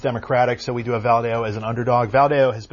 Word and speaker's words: Valadao: currently represented by Republican David Valadao, I think I'm pronouncing that wrong. – Valadao, thank you, and being challenged by Democrat Randy Villegas.